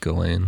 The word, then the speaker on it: Ghislaine: And then it's pronounced Ghislaine. I like it. – Well, the S is silent.